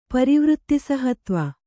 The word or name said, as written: ಪರಿವೃತ್ತಿಸಹತ್ವ